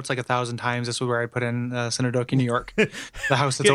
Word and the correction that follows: Synecdoche: Synecdoche, like the literary device.